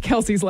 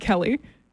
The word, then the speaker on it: Kelce: Localized it and mispronounced Kelce's last name. – What did I say? – You said Kelly. Oh, I did? – Yeah. – Well, I meant Kelce.